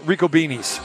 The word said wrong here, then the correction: Ricobene's: What's the proper pronunciation Rico now? – Ricobene's. – Yeah, it, it, it's Rico. It's Ricobene's. Get it right.